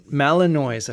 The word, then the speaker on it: Malinois: Belgian Malinois, I think it's pronounced, isn't it? – Yeah. – They are...